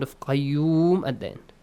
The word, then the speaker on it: قيوم: قاف has to be pronounced heavily. قيوم.